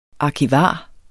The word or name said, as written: arkivar